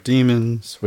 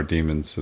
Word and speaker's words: daemon: It's spelled D A E M O N, but there are demons in the computer.